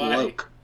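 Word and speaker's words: Lōc: it's Tone Lock." Like, I think I it's pronounced Loke.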